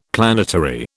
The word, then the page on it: planetary: British English